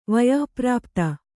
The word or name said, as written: ವಯಃಪ್ರಾಪ್ತ